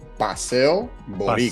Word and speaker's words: Boricua: It's pronounced Paseo Boricua.